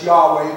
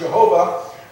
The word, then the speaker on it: Yahweh: And so, it's the Jewish national name for them it's Yahweh, but we pronounce it Jehovah.